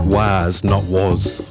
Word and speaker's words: Was Not Was: And they're saying, pronounce it like this. Was Not Was.